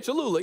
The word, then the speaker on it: Cholula: I don't know if I'm pronouncing it correctly, but Cholula? – Cholula.